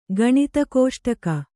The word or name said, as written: ಗಣಿತಕೋಷ್ಟಕ